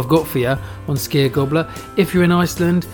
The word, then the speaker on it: skyr: If you want to try some skier, it's spelled S-K-Y-R.